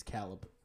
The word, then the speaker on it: Caleb: My name is, that's how you actually pronounce my name. It's Caleb.